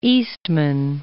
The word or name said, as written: Eastman